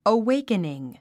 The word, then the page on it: awakening: əwéikəniŋ; アウェイクニン